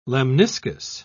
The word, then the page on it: lemniscus: British English